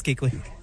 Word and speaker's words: Kuechly: Yeah, it's Kuechly.